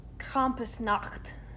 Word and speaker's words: Krampusnacht: How do you say this word? Krampus. – I've been practicing my German specifically for my first story. – Krampus Nacht? – I'm assuming it's Krampusnacht.